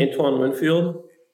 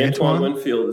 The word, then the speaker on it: Antoine Winfield: I think Emma's a Tampa fan with – Anthony Winfield? Is that how you say his name? – Antoine Winfield. – Or Antoine Winfield is coming back for his third season.